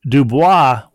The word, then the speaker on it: Dubois: To answer your question from a couple of episodes ago, Dubois, Wyoming... where I grew up, I read that as dubious. Dubois, Wyoming, where I grew up, is not pronounced like a French name, but instead rhymes with Sioux Boys.